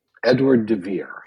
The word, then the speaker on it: Edward de Vere: I would say that my favorite playwright, I just want to make sure I pronounce this correctly, would be Edward de Vere.